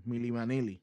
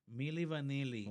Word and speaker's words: Milli Vanilli: Milli Vanilli. – Ok. Milli Vanilli.